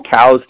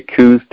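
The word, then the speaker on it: Coues: this if if you're listening out there wondering what Jim's talking about, there's this whole uh, group, different groups that you know call them cows deer, which is actually the correct pronunciation, and then there's a bunch that say coos deer, and regardless of what you call them, they're f- fantastic deer.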